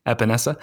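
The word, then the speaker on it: Epenesa: And I don't think he's getting past the Lions or the Patriots if it comes to it, but Espinosa, am I saying that right, from Iowa, the edge rusher? – Epinesa.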